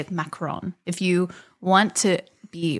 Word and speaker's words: macaron: but the correct pronunciation of your confections is yes. – A macaron.